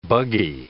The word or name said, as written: buggy